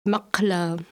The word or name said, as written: makla